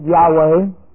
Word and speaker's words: Yahweh: Or you can try and have that YHWH, pronounce it Yahweh.